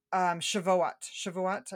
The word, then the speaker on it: Shavuot: And when we were in Jerusalem, we were there for Shavuot.